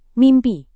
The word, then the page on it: mimpi: Indonesian